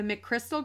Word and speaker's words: McChrystal: She transitioned out of the government service in 2014 and joined the McChrystal Group.